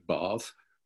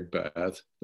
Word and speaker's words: Bath: The British pronounce it Bath, but if you say Bath, they know what you're talking about. They just know you're a Yank.